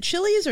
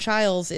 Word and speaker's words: Childs: Like the Oregon State quarterback, Aiden, is it Chili's or Childs? I never know how to say his name. – I don't think I've ever seen the name Childs pronounced as Chili's.